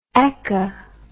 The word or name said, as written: eka